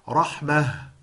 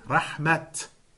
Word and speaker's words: rahmah: And sometimes you'll see the word rahmah and you pronounce it rahmah. But at other times you see it and you pronounce it rahmat. And you have to know when it's supposed to be read in those ways, when it's supposed to be explained.